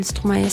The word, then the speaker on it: Stromae: Stromae.